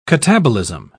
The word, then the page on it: catabolism: British English